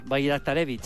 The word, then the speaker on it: Bajraktarević: El primero es un bosnio del PSV que se llama, voy a decirlo tal y como se escribe, Esmir Bajraktarević. – Sí, señor, Bajraktarević.